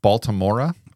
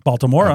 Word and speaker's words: Baltimora: So this is Tarzan Boy by a group called Baltimora. – Baltimora.